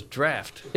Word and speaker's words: draught: Why is it spelled D-R-A-U-G-H-T? – Well, that word is draft.